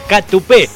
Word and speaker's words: Catupé: Catupé, con, acento en la E final, palabra aguda con acento en la E, Catupé. Y se pusieron y se mataron poniéndole Catupé Cumachu.